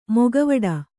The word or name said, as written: ಮೊಕವಾಡ